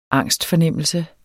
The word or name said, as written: angstfornemmelse